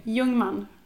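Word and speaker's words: Ljungman: And when I said to Magnus, like, when I try to spell it to other people, I'm just like, L, okay, so this will be Swedish, but L-I, Ungman.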